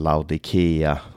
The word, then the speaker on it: Laodicea: Eh, Laodicea uttalas ibland Laodikea och så vidare.